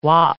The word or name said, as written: whop